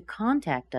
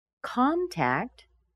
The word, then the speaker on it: contact: Contact, there's another verb. Con, the C-O-N, is what we're stressing. Tact, by the way, is a secondary stress.